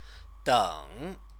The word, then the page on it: deng: chinese